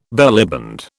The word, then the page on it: bellyband: British English